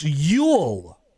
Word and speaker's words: Oail: John, it is pronounced Yule. – Oh, Yule. – Yule. – Yule. That sounds more like a name. – O-A-I-L is pronounced Yule?